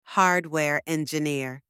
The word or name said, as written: hardware engineer